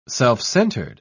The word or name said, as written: self-centered